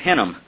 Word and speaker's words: Hinnom: It's the Valley of Hinnom. Hinnom is spelled H-I-N-N-O-M,